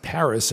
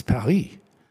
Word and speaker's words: Paris: It's not pronounced Paris in French. It's Paris.